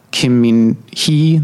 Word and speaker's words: Kim Min-hee: Kim Min-hee, I'm saying that right, yeah.